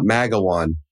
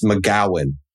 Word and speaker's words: McGowan: It's like, it's not Magawan, it's McGowan. Because I apparently can't speak Scottish or Irish.